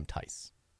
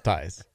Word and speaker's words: Theis: By the way, apparently Daniel Theis, he pronounced the last name Theis. I didn't even know that.